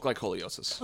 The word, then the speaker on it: glycolysis: Is that how that's pronounced? Glycolysis? We'll say We'll go with glycolysis.